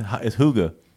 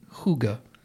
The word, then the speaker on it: hygge: It's Hoogah. – Spell it H-Y-G-G-E. Hoogah. Kind of like Sade. It's Danish, though.